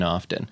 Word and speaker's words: often: All that's left is to decide how often Matt Gourley guest stars. And then it's and it's, a, which it's and, like, it, and you don't have to pronounce the T in often.